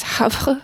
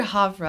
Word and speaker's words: Havre: It's written Gaver, but I think it's Havre or Havre. Yeah. Do you know? – I've heard Havre.